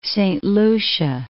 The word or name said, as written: Saint Lucia